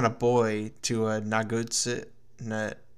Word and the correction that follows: Nagatsuka: Nagatsuka. – His friend. I'm yeah. butchering these Na- names. Nagatsuka.